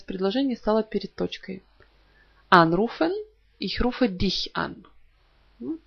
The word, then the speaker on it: anrufen: Приставка «an» – это приставка отделяемая, потому что на нее падает ударение «anrufen».